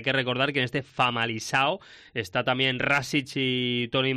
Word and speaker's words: Famalicão: En el Famalicão, ¿se pronuncia así? ¿Se agarra? – Famalicão. – Famalicão.